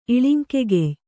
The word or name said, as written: ಇಳಿಂಕೆಗೆಯ್